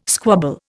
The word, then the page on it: squabble: Indian